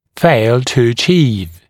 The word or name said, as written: fail to achieve